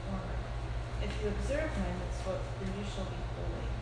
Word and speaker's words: mitzvot: These words at the conclusion of verse 40 could be taken as a relational reason for keeping the mitzvot.